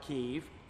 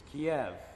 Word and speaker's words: Kiev: It is not Kiev, it's Kiev.